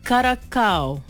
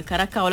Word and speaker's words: Curaçao: Ini dia antara kisah paling luar biasa di Piala Dunia 2026, ialah kemunculan pasukan dari Curaçao. InsyaAllah lah betul lah EJ, sebutan EJ ni. Ejaan dia C-U-R-A-C-A-O. A-A-O pula. A-O. Curaçao lah kan.